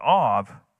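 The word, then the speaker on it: Av (אב): The Hebrew for father, it's pronounced, it's A, the letter's A-B, but Av.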